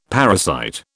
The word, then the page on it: parasite: British English